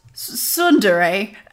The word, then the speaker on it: Tsundere: Tsundere